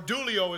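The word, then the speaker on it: dulio (δουλεύω): There's one, dioleo, I'll give you this one, or dulio is the way that it's pronounced in the Greek.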